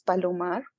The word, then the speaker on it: Palomarr: Oh, by the way, my real surname is with just one R. It's Palomarr. But because of the English language, I put a double R so I make sure you pronounce it.